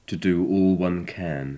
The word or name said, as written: to do all one can